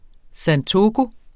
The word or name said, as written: santoku